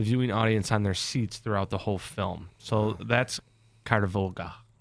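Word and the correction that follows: Covadonga: Right? – Kovadonga. – Oh my goodness. It's hard to pronounce. – Yeah. – Sorry. – Kovadonga.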